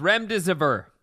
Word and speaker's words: remdesivir: It's pronounced remdesivir.